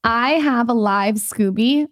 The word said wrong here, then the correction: SCOBY: Scoby. – How do you say it? Scoby? – Yes.